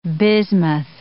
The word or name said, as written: bismuth